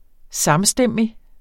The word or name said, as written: samstemmig